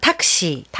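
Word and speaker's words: 탁시 (タクシー): 일본어는 탁시. – 탁시. – 딱시. 들어본 것 같아요. – 탁시. – 예. 네. 탁시.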